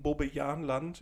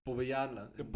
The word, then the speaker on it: Bobbejaanland: Ich war mal im suspekten Bobbejaanland in Belgien. – Bobbejaanland.